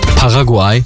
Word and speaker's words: Paraguay: Morgen spielt ja heute um 5 Uhr beziehungsweise jetzt spielt die Türkei gegen Paraguay, so wird es ausgesprochen.